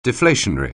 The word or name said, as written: deflationary